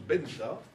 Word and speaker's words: Venta: ça écrit V-E-N-T-A, mais ça se prononce Benta, comme le V en espagnol,